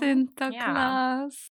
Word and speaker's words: Sinterklaas: Und die hatten auch eine Weihnachtsmannfigur namens Sinterklaas. – Das habe ich oh, bestimmt auch falsch ausgesprochen. Sinterklaas. – Genau,